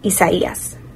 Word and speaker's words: Isaias: The next name in the Atlantic hurricane season will be Isaias. – Yep. – Isaias.